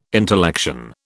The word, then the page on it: intellection: British English